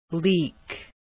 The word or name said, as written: leek